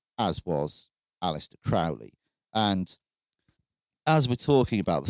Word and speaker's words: Crowley: Aleister Crowley, I called him Crowley then. Crowley is the right one. Other people call him Crowley.